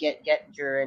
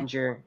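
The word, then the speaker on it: Oettinger: As I think we mentioned, Jake Gettinger and or however you pronounce it. – Yeah. Ottinger, I think. – Yeah, what they were saying on TV last night. – Ottinger and the coaching as well for Dallas Stars.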